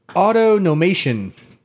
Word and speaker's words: autonomation: Top answer is the Japanese term for autonomation. – Am I right? Yeah, not that? Automation, autonomation. – Autonomation, yes. – I said that right, yeah? – You did, yes.